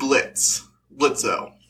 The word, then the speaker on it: Blitzo: So the main characters of Helluva Boss are Moxie, Millie, Luna, and Blitzo. The O is silent now.